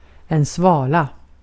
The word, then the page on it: svala: /ˈsvɑ̀ːla/